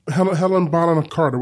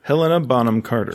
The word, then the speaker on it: Helena Bonham Carter: Helena Bonham Carter. What's how? Say her name. You want to try that again? – Say it for me. – Take two. Helena Bonham Carter.